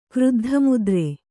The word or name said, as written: ಕ್ರುದ್ಧಮುದ್ರೆ